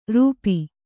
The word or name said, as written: ರೋಪ